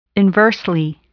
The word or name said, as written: inversely